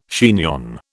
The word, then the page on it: chignon: British English